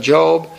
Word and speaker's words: Job: But we know the man's name, and we know that that's pronounced Job.